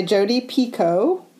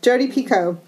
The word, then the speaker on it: Picoult: I got to hear Jodi Picoult at Books and Company in Dayton, Ohio, years ago, and she said that you pronounce her last name like Pico T. The problem for me is I don't often order pico tea at restaurants, so I'm not sure if it's pronounced pico or pico, Piku.